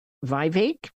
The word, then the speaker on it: Vivek: You know, I don't know, Vivek rhymes with cake.